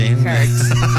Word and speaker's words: hertz: Lo único que sí tengo que decir es que me siento muy mujerts diciendo hertz. – Okay. – ¿Sí? Hertz. ¿Sí? Tal vez así no se debe pronunciar. ¿O sí? ¿Cómo sería? – Hertz. Hertz.